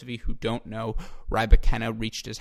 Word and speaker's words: Rybakina: That being said, again, something I know we have all enjoyed during this 2020 season is the emergence of Elena Rybakina. And to clarify, it's not Rybakina. I know it's spelled R-Y-B-A-K-I-N-A, but she made that clear. It is not Rybakina.